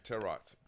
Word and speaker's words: tarot: Yeah, that's how you pronounce it. – Really? – Tarot, yeah. – You got to pronounce the T? – Yeah. – All right, bet. There we go. The more you know. – It's like parrot.